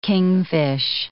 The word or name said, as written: kingfish